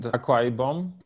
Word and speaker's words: Akwa Ibom: Well, you mentioned Akwa Ibom. Am I pronouncing it correctly? – Yes.